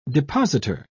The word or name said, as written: depositor